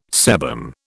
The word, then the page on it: sebum: British English